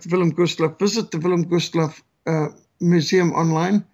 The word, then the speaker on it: Wilhelm Gustloff: And when I say Willem Gustloff, you pronounce it more like Wilhelm Gustloff. But of course, the Germans would have pronounced the W's as a V. And because we speak Afrikaans here, we do the same thing in South Africa.